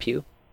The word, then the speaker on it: Pugh: Did I say that right? Is it Pugh? – Yeah.